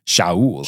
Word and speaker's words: Shaul: And what's a good Hebrew pronunciation of Saul? – Shaul.